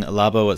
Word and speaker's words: Labo: Is Labo. – Adding VR to the Switch and kind of finally answering these rumors? – I mean, I'm sure it'll be fine. I think, like everything Labo, it's not really something that I'm personally...